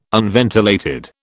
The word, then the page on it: unventilated: British English